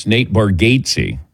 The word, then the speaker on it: Bargatze: I always want to say Bargatze. But then when we met him in Vegas, or Tahoe, he said, no, he goes, my family actually mispronounces it, but it's Nate Bargatze.